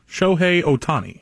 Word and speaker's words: Shohei Ohtani: Do you know how to say his name? Shohei Ohtani? – Shohei Ohtani.